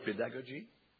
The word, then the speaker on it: pedagogy: Do you have the word pedagogy? Pedagogy. The emphasis is on the go.